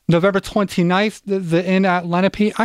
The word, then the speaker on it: Lenape: So finally, Cigna kind of smacked me in the back of the head and said, this is how you say it. So it's Lenape.